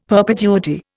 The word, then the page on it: perpetuity: Indian